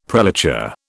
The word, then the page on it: prelature: British English